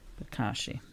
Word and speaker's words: Bokashi: Bokashi. – Oh, the Bokashi yeah. bin. – Yes. – how do you how do you spell it? – It's B O K A S H I. – Bokashi.